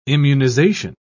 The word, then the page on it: immunisation: British English